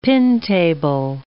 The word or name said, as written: pin-table